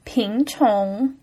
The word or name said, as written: pin2qiong2